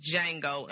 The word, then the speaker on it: Django: Unless the D is silent, then it's just Django Unchained.